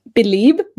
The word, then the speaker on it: Belib: But this company that I'm going to pronounce Belib, B-E-L-I-B, is who's providing these chargers.